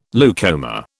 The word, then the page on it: leucoma: British English